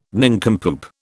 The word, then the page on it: nincompoop: British English